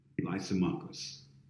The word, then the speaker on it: Lysimachus: Lysimachus, all right? Lysimachus, L-Y-S-I-M-A-C-H-U-S, all right?